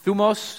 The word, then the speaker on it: thymos: The word is, it's spelled thymos, but it's pronounced thumos. Everyone say thumos. Thermos, right? It sounds like that.